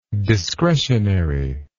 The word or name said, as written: discretionarily